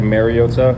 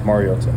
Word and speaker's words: Mariota: I think it's been said over the years, like Mariota, which is incorrect, and it's pronounced Mariota.